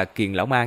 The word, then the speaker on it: kiền lão ma: Chú thích, ở mấy tập trước á, cái ông nội Âm La Tông này á được gọi là càng lão ma, tự nhiên bây giờ bị dịch là kiền lão ma, nên từ đây về sau lát sẽ đọc là kiền lão ma nha.